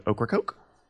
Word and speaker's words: Ocracoke: Do you know an island off the coast of North Carolina called Ocracoke? – Oh, sorry, spell that? – O-C-R-A-C-O-K-E, Ocracoke.